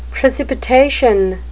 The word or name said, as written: precipitation